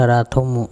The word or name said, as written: arathomo